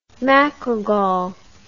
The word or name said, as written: macrogol